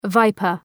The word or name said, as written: viper